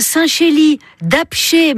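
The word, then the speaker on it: Saint-Chély-d'Apcher: Vous avez un Saint-Chély d'Apché, mais alors ça s'écrit avec un R, mais on prononce pas le R.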